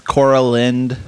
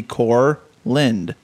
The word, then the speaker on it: Korlynd: Yes, Coraland. I don't know where they get the A, because there's substitute teachers will like pull up the list, or would pull up the list of kids, and they'd come to my name, which is spelled K-O-R-L-Y-N-D. To me, it makes pretty good sense that it would be Corland.